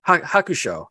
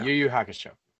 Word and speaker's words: Yu Yu Hakusho: So the last track you guys heard, I'm gonna say this name wrong, so please forgive me. Yu Yu Hakusho. Am I saying that correctly? – Hakusho. – Hakusho. Okay, there we go. – Yu Yu Hakusho.